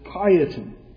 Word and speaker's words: Cajetan: Now, to spell his name, it's an Italian name, you spell it C-A-J-E-T-A-N, and you might be inclined to pronounce it differently. But it was an Italian name, so we pronounce it Caiaton.